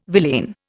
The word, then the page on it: villain: Indian